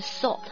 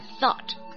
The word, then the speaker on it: thought: 记住了，思想是要咬舌头的， thought 不要读成了 salt 哈，是 thought。